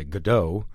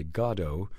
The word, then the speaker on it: Godot: Most Americans say Godot. And yeah. there are great arguments for that, by the way. Most Brits and Irish say Godot.